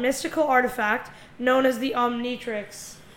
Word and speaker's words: Omnitrix: Omnitrix.